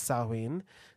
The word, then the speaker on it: Samhain: So autumn is the top of the Wheel of the Year, and Samhain, spelled S-A-M. – Samhain. – Yes, yeah, S-A-H-M-A-I-N, which is one way, but it's pronounced Samhain.